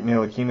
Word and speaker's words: Ntilikina: Frank Ntilikina is actually playing more of... Hey, I said it right! – He did. The T is silent.